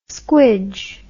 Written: squidge

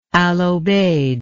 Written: al obeid

